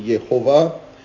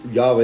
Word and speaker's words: Yahweh: nobody really is sure how it was pronounced, okay? But it seems it's probably Yahweh or possibly Yehovah. But it's Yahweh is usually how it gets pronounced in modern circles today. Thus you shall say, the Lord, L-O-R-D, you notice in your English Bibles, if you look there, you'll see it's all capital letters for Lord.